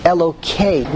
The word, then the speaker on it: Elokei: Number one, it's very interesting that when we say Hashem's name, and really when we do not want to say Hashem's name, we say Elokei. We pronounce a Hey as a Kuf.